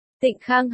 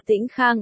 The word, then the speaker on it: Tịnh Khang: Tịnh Khang hay còn được đọc là Tĩnh Khang ở đây là chỉ niên hiệu của vua ở thời nhà Tống.